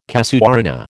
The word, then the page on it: casuarina: American English